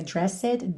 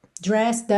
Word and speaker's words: dressed: É dressed, lembra que esse E final aí não é pronunciado, tá? Então não é dressed, dressed up to.